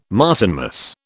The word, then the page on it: Martinmas: British English